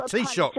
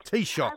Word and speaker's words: Taoiseach: It's the Taoiseach. – Of Ireland. – Taoiseach.